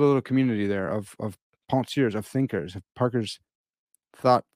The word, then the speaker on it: Penseurs: I'll pronounce the second French word, but not the first one correctly. Penseurs.